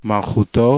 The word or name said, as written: malchuto